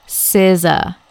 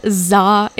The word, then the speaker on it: SZA: And we have to pronounce this one is SZA. It's SZA. It's not ZA. It's not CZA.